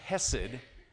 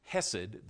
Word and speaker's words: chesed: There are a multitude of words that the Hebrew word hesed, and the C is silent, but it's spelled C-H-E-S-E-D, hesed, the word hesed means.